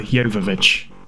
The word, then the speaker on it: Jovovich: Mila Djokovic. – Mila Jovovich.